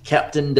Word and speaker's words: Kapton: K-A-P-T-O-N. Captain Dillon. So I hope that's how you say it. If not, then I'm really butchering that name.